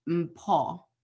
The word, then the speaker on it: Mpho: So I go by Mo here, but the way that it's actually pronounced, it is a South African name and it's pronounced Mpo.